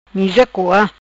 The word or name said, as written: Coa Miza